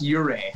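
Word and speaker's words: Ouray: And Oray. Am I saying that right? Oray? Is that the... – It's pronounced Uray.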